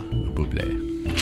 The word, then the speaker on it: Bublé: Bublé. – He's a very popular current day crooner. – Okay, sorry. – Bublé.